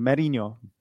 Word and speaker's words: Merino: How do you pronounce his name? Merino. – Mourinho. Merino. I would say Merino. – Merino, because there is also a famous manager, Jose Mourinho. Yeah. So I keep confusing those two, mispronouncing his name.